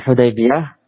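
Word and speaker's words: Hudaybiyah (حديبية): Ini cara bacanya Hudaybiyah. Dengan membawah ha, memfathah dal, mensukun ya, mengkasrah ba Hudaybi.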